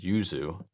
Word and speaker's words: Yuzu: I think that's how you pronounce it. Yuzu. Yeah. Y-U-Z-U.